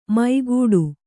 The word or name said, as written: ಮೈಗೂಡು